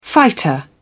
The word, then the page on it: fighter: British English